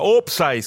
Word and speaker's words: Opsai: Gioco, sì, solo che invece di tradurre la parola come si è fatto in Italia, qui si è conservata la radice inglese e si è adattata alla pronuncia locale da gente che l'inglese non sapeva dove stava di casa. – E quindi quando il guardaline alzava la bandierina, qualcuno sugli spalti gridava Opsai, scritto proprio così, O-P-S-A-I.